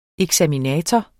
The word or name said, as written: eksaminator